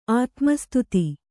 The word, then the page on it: ಆತ್ಮಸ್ತುತಿ: Kannada